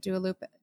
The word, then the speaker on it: Dua Lipa: Dua Lipa.